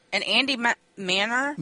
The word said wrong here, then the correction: Manar: Manar. – How do you say Andy Manar, yeah.